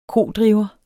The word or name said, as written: kodriver